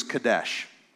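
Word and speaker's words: kadesh (קדוש): Let's just say that it can seem very much, but the word holy in Hebrew is kadesh. That's how you pronounce it in Hebrew.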